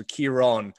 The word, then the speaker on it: Kieron: So phonetically, like Keyron, so Kieron, and it always gets messed up between Kieran, Kieron, Cora, like just all over.